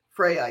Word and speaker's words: Freya: Do you pronounce that Freya? – Freya, yeah, that's after the goddess.